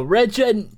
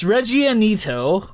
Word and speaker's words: Reggianito: So my cheese rating is Reggitanio. Oh, no. Reggianito, which is... – you can't even pronounce it. – It's Reggianito, which is made from unpastured and unpastured cow's milk, and it is from Argentina.